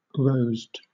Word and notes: rosed: British English

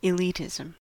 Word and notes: elitism: American English

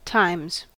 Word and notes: times: American English

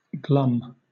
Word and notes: glum: British English

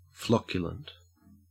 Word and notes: flocculent: Australian English

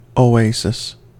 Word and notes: oasis: American English

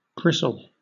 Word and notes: bristle: British English

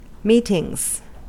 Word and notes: meetings: American English

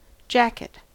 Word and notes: jacket: American English

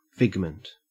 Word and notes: figment: Australian English

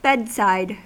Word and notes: bedside: American English

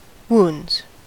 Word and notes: wounds: American English

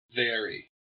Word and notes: vary: American English

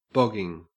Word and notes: bogging: Australian English

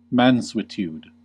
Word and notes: mansuetude: American English

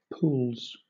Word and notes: pools: British English

